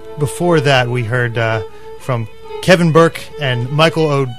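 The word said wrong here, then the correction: Mícheál: Michal O'Donnell. – Michal O'Donnell. Okay. Michael. It's spelled Michael, folks.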